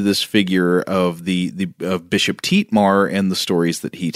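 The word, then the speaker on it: Thietmar: It is spelled T-H-I-E-T-M-E-R, but I think it would be Tietmar, sort of Dietmar.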